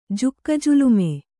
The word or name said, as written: ಜುಕ್ಕಜುಲುಮೆ